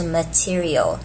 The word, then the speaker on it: material: OK， material 这个字呢，请注意中间这个 ter 不是念 ter，它是念 t，所以是 material，所以要注意重音在 t 身上，所以是 material，的的的的 material。